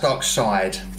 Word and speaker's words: Darkseid: It's pronounced Darkseid.